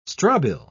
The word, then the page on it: strobile: British English